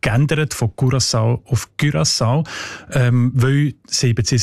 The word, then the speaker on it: Curaçao: Curaçao heisst die Insel, habe ich gelernt. – Sorry? – Mit Ü, genau.